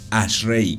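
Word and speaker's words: asrei (אַשְׁרֵי): Del hebreo se pronuncia asrei, asrei como con una S y una breve H, digamos, ¿no?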